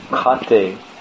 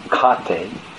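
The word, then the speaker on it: khate: Sitting on a cot, kate, it's interesting, K-H-A, long A-T-E, kate, means cot.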